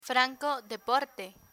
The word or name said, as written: franco de porte